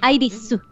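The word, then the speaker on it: Airisu (アイリス): Iris, como se pronuncia en japonés. Me gusta mucho el trabajo como personaje. – Airisu.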